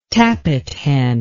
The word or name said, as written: tappithen